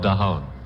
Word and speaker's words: Daahoud: From What's New to a tune that is spelled D-A-A-H-O-U-D, and we pronounce it the same way you spell it. How about that? It's called Daoud.